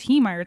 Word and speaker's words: Heemeyer: I think it's Haymeyer. It's H-E-E. – Haymeyer, I think is how he says Haymeyer. – It. Haymeyer? – Haymeyer? Why would H-E-E Meyer be pronounced Haymeyer? – I don't know.